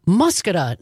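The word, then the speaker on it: Muscoda: And I did not pronounce the town of Muscata right. I hope I'm getting that right now. Thanks to Robert in West Dallas. And I think, Jane from Madison listening on WMDX. Muscata, not Muscata.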